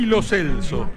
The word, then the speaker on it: Lo Celso: Hernán Feller hoy tiene, como todos los relatores, el cruel desafío de haberse enterado hace 2 horas que es Lo Celso. Porque los jugadores, papel en mano, dijeron ante las cámaras de FIFA cómo se pronuncian sus nombres. Y el número 11 argentino dijo Giovanni Lo Celso. O sea que pronunciamos mal su apellido más de 10 años.